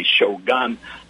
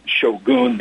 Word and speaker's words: shogun: Americans say shogun. I say shogun, the Japanese pronunciation.